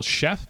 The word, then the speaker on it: Shef: And the third one is called Chef, but it's spelled as S-H-E-F, so Chef.